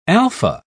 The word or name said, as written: alfa